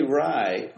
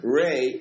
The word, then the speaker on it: Rai: When I say Rai, when I lump it with Eloi, Tu, and Lofty, it's spelled R-A-I, like Italian TV, not R-H-Y-E-E. So R-A-I, that Rai, Ray, we'll call it Ray to be slightly different, Ray, Eloi, Tu, and Lofty are the four aspects of Rai.